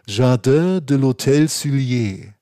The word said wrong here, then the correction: Sully: Ja? Also ein Garten, Garten des Hotels Sully. Sully, so sagt man.